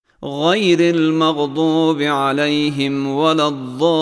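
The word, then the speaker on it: الضالين: در تلفظ حرف ضاد فرمودن حرف ضاد از تماس کناره زبان با دندانهای آسیای بالا تلفظ میشه. طرف راست یا چپ تفاوت نمی کنه، اما تلفظ ضاد از طرف چپ آسان تر هست. بنابراین کناره زبان به دندانهای آسیای بالا ترجیحاً سمت چپ متصل میشه و این حرف تلفظ میشه، غیر المغضوب ولا الضالین، غیر المغضوب ولا الضالین.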